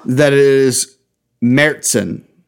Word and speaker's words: Märzen: Oktoberfest is a Märzen. – Okay. – Which I learned is the right way to say that.